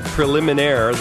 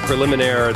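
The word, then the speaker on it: Préliminaires: A new album from Iggy Pop called Preliminaire. That's the French pronunciation of preliminaries. Preliminaire, it's called.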